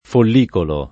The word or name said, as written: follicolo